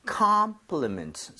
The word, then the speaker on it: compliment: 그리고 발음할 때 여기 강세가 가면서 입 모양이 벌려져요. 대부분. – 네. 오케이. – O는 O의 강세 가면 대부분 이 모양 벌려집니다. – 네. – 자, compliment. So, not 콤, 이렇게, 콤, 이러면 안 돼요.